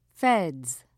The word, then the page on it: feds: British English